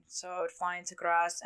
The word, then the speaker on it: Graz: Graz is like, the only reason why I know how to pronounce that correctly is because I had a customer the other day that was from Germany, and they were telling me.